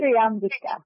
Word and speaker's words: Triambika: Triambika, that's right, yes.